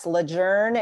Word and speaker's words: Lejeune: Lejeune is the way that wow. it needs to be pronounced. And there's a whole long backstory about how it's Lejeune is how folks have been saying it, but that's actually incorrect. So Lejeune, you heard it here first. Lejeune.